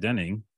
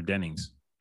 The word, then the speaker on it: Dennings: Is that how you say your name? Denning or Dennings? – I think it's Denning singular. – I don't know what her, what's her name in this show again? – Oh, it's plural.